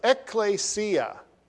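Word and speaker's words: ekklesia (ἐκκλησία): But there's a word in the New Testament which is almost always translated church, and that's one up there, and it's pronounced ekklesia. Ekklesia.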